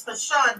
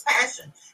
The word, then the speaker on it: Pashun: And I pronounce the mother's name is Pashun, but it's Passion. It's spelled P-A-S-H-U-N. It looks like Pashun.